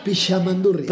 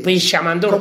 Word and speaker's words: Pixamandurrie: Sí, la X se pronuncia Pixamandurrie. – Pixamandurrie. – Como una SH, algo así.